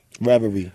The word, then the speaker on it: Reverie: Now, how do I pronounce this next record, man? Reverie. – Reverie, yeah.